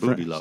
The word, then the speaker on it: Boudleux: Love that name, Boudelot. Boudelux. It's pronounced Boudelot.